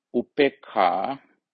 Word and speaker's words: Upekkha: M-M-T-T-A, long A, Metta. And the last and the number 10, Upekkha, that's Upekkha, U-P-E-K-K-H-A. U-P-E-K-K-H-A, Upekkha. If I go through the list one more time, Dana, Sila, Nekkhamma, Panya, Virya, Kanti, Satcha, Adhitthana, Metta, Upekkha.